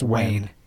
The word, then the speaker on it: Nguyen: I did not know that NGUYEN was pronounced Nguyen. – I knew that it was not pronounced the way it's spelled, but I didn't know how to pronounce it. – I think says Wax says it's Nguyen. – No, I think it's Wayne. – Wayne. It's just Wayne.